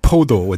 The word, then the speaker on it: 포도: 포도, with a hard P, right? – That's right.